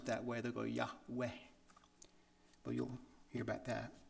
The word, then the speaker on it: Yahweh: And we've heard a Jewish, born-again Jewish speaker says it's pronounced Yahweh.